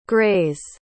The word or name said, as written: grays